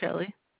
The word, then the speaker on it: Shelie: My name is Shirley. – Shirley? I think you called earlier. How you spell that? – Yes, ma'am. S-H-E-L-I-E. – S-H-E-L-I-E? – Yes, ma'am.